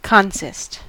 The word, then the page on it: bestehen: /bəˈʃteːən/